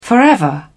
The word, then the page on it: forever: British English